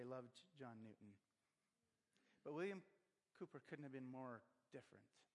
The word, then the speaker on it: Cowper: Cowper, depending, I think it's pronounced Cooper, but it's, for us Canadians, it looks like Cowper, C-O-W-P-E-R.